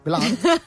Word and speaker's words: gland: Llavors, aquest botó que sobresurt s'anomena gland, Gland. M'agrada pronunciar-ho així, Gland, perquè és G-L-A-N-D, Gland.